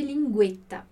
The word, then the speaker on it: linguetta: que a gente vai chamar de linguetta, ok? Então, linguetta. Aqui provavelmente você já pronunciaria essa palavra dessa forma, né? Importante aqui o som delle doppie consonanti, linguetta.